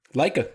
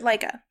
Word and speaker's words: Laika: So first we're going to get into the animated, is it pronounced Laika? – Laika, yep. – Laika.